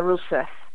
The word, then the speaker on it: Rousseff: Yes, Dilma Rousseff.